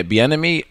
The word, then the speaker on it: Bieniemy: Bieniemy. – Yeah, Bieniemy only got an OC job with fucking the Commanders.